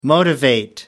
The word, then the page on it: motivate: American English